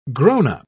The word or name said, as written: grownup